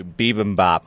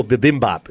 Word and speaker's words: bibimbap: Bibimbap. – Oh, bibimbap. And you said it wrong. It's bibimbap.